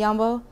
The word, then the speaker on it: jambo: Yambo, or si yambo, that's one that you might have heard a little bit more. It's pronounced jambo in different Swahili countries. In Zanzibar, it's yambo.